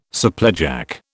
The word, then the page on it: supplejack: British English